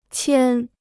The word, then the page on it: 牵: qiān